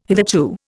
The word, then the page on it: hitherto: Indian